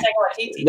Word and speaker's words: Waititi: Oh yeah, Waititi.